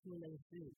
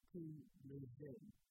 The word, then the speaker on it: Coliseu: And the name of the gym is Kule Zoo. I think I'm pronouncing it correctly. Kule Zoo. It's spelled C-O-L-I-S-E-U. And it's Portuguese for amphitheater or coliseum.